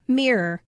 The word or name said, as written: mirror